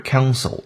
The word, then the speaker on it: console: counsel